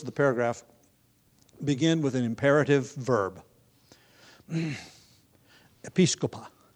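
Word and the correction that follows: episkopeō: Episcopo. I left the syllable out. Which is translated, see to it. And I used the, I mangled the pronunciation of the Greek verb because its noun form is more familiar.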